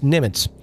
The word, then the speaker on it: Nimitz: I used to be in the Navy, and the first ship I was stationed on was the USS Nimitz. Did I say that right? – You did.